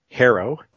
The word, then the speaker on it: Harrow: It's an English word. Harrow. – Harrow. – Like harrowing experience, or to harrow, you know, harrow the soil.